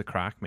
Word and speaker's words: craic: See, but of course, it's spelled C-R-A-I-C and not C-R-A-C-K. Not like, can I have some crack? – Yeah, what's the crack?